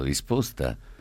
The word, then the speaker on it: risposta: Caro Pino, prego te e i tuoi ospiti di non pronunciare mai le parole dopo, posto, risposta, 14, con la O chiusa. È sbagliato e di cattivo gusto. I toscani pronunciano con la O chiusa. I toscani pronunciano in maniera scorretta. La lingua italiana è ufonia. Aiutatemi. Perché? E si dice dopo, risposta, posto.